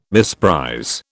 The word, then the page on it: misprize: British English